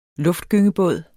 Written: luftgynge

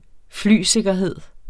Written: flysikkerhed

